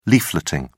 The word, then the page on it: leafleting: British English